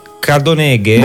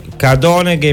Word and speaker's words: Cadoneghe: Cadoneghe. – Buongiorno. – Cadoneghe in provincia di Padova.